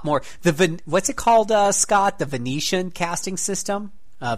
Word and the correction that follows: Vancian: Vanakin, Vancian. Vancian. I never pronounced it right the first time.